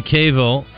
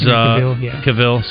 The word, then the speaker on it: Cavill: Henry Cavill, who is... – Cavill, sorry.